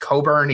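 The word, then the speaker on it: Cockburn: Their big guy is named Kofi Coburn, even though it is spelled in a way that does not sound pleasant.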